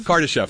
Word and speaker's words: Kardashev: And we're trying to understand, we want to sort of, you know, go beyond the sort of Karadash, if I can pronounce it right, the Karadashov. I always get it. – Hold on, I've got the Kardashev? – Kardashev, thank you.